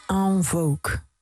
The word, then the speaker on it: En Vogue: En Vogue. Hoe spreek je dat uit? – Ja, precies zo.